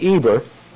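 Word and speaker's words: Eber (עֵבֶר): Pronounced E-B-E-R, Eber.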